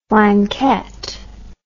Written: flanquette

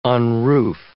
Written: unroof

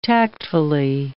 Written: tactfully

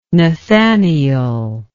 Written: Nathanael

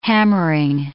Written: hammering

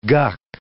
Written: guck

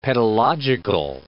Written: pedological

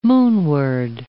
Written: moonward

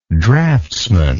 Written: draughtsman

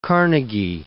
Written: Carnegie

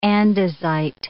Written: andesite

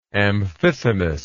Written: Amphithemis

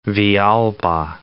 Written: Villalba

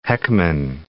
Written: Heckman

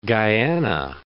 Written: Guyana

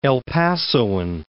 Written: El Pasoan

